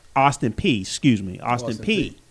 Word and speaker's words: Austin Peay: Also, Austin Prairie, if I pronounced that correctly. – Prairie. – Austin Peay.